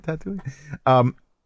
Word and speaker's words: Tatooine: Tatooine.